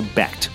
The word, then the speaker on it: Becht: Yes, Becht, yeah. Although it is in some parts of Germany pronounced Becht, but I won't go into semantics there. No, right. Becht is the way it's been pronounced.